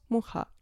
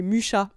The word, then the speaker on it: Mucha: Je pense à Kundera, je pense à Kupka, je pense à Mucha, du coup, qui se prononce Mucha, j'ai découvert ça, mais Mucha en français, vu que c'est en France qu'il a quand même fait le plus gros de sa production,